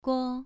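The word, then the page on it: guo: tone 2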